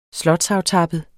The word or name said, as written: slotsaftappet